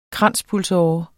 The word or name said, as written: kranspulsåre